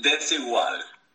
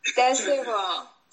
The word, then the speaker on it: Desigual: Yeah, Desigual. – Desigual.